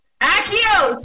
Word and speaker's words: Accio: Accio? – Accio. – Accio! See? I knew it! I've been pronouncing Accio! – Think of like the Harry Potter spell, like Accio.